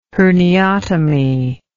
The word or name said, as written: herniotomy